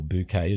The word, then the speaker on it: Bucket: Now, do you remember, Mike, that English TV comedy called Keeping Up Appearances with Hyacinth Bucket, or Bouquet as she pronounced it? – Yeah, I do, actually.